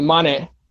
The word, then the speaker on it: Mane: It's actually Mane. Pronounce it as M-A-N-E, but it says as Mane.